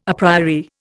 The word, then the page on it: a priori: Indian